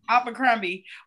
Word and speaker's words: Abercrombie: I remember, and this is back further, this is like us as child, you know, us being younger, but I remember when Abercrombie, am I saying it right? Abercrombie uh-huh. was like the suburban kid yes.